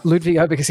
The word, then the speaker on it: Ludvig Åberg: Ludvig Åberg, whatever his name is. I think he came second or third in the Masters or something. – Ludvig Åberg, yes.